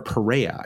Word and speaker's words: Pereira: What about Pereira? Or Perea, I think actually is how you say that.